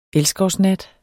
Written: elskovsnat

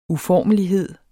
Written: uformelighed

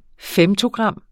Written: femtogram

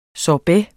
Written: sorbet

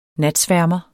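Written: NATSVÆRMER

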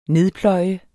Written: nedpløje

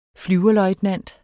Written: flyverløjtnant

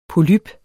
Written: polyp

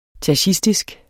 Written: tachistisk

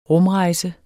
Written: rumrejse

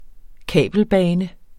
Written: kabelbane

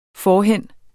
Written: forhen

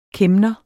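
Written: KÆMNERE

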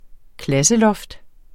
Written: klasseloft

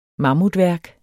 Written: mammutværk

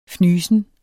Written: FNYSEN

